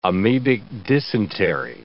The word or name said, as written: amebic dysentery